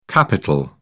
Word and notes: capitol: British English

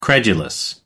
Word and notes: credulous: American English

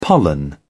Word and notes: pollen: British English

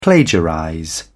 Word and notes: plagiarize: British English

